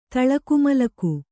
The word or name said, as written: ತಳಕುಮಲಕು